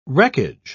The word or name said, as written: wreckage